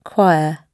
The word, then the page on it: choir: British English